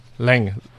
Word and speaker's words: Laing: Lang. L-A-I-N-G. – Wow. – I hope I'm pronouncing this properly, Lang.